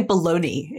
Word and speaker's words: bologna: So things that I mentioned, sausages, bologna, how do I pronounce it? – We just say bologna in the US.